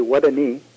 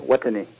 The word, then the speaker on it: Wutuni: It's, if you put your hand on your knee and you say Wutuni, and you put a hard emphasis on the T, that's it. Wutuni.